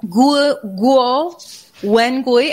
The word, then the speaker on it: Guo Wengui: Guo Wengui, am I saying his name right? – Yeah, you said almost perfect.